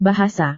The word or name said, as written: bahasa